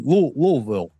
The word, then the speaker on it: Louisville: It's definitely my favorite trip of the year, and looking forward to hanging out with you in Louisville. – Yeah, there you go. You said it right. Louisville.